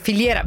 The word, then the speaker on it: filiera: C'è un piano per incentivare ristoratori, grande distribuzione, tutta la foliera. Adesso arriverà l'SMS, non si dice foliera, si dice filiera.